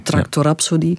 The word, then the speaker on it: Tractor Rhapsody: Tractor of Tractor Rhapsody, hoe moet ik het uitspreken? – Tractor Rhapsody. – Tractor Rhapsody. – Absoluut.